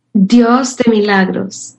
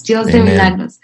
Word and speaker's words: Dios de milagros: Dios de milagros. God of miracles. Dios de milagros.